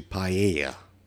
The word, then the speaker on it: paella: Apparently, I mispronounced paella. It's actually paella. And I said it wrong. – So, no L. – No L. There's two L's in it, which I guess threw me for the loop.